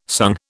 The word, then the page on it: sung: British English